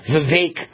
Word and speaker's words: Vivek: It's not Vivek, it's Vivek, because it rhymes with cake, he said. Vivek.